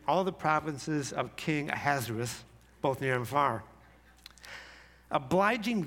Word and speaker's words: Ahasuerus: It turns out Ahasuerus is the correct pronunciation as far as this non-Hebrew-speaking person can muster. But you know what my favorite pronunciation is? Ahasuerus. Ahasuerus. Look at the spelling. You'll see I'm right. It's Ahasuerus. Because this man is a hazard to us.